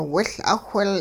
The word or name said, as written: wiL'awhil